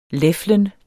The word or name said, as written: leflen